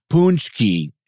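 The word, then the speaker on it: pączki: Pawn check would be singular. – Uh-huh. – And punch key is plural. And this has got to be Polish, right? – Yeah. Oh, yeah. It's got to be, because it's spelled P-A-C-Z-K-I. – They love the C-Z-K.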